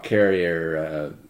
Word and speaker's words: Carrier: Let's not, okay, but it's still, it's the most famous rock carrier work. – It's if you don't mind me correcting you, it's pronounced Carrier.